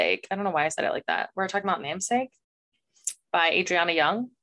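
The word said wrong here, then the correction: Adrienne: Is that how you say her name? Adrian Young. Namesake Adrian. By Adrian Young.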